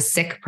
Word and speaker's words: Sikh: I'm a Sikh, pronounced Sikh, not Sikh, as we decolonize language.